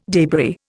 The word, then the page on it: debris: Indian